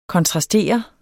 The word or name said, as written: KONTRASTER